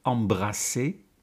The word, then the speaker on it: embrasser: Embraser, met een enkele S, is niet te verwarren met embrasser, met een dubbele S. He, je weet, één S spreek je uit als een Z en twee S'en als een S.